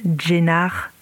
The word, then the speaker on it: Caner: Enfin, si, ça s'appelle Caner, mais ça se prononce Jenar. Voilà, ils écrivent inversé.